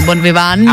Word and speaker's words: bonviván: Píše se to Bonviván T. – Ale čte se to Bonviván.